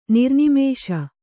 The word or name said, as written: ನಿರ್ನಿಮೇಷ